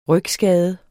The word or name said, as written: rygskade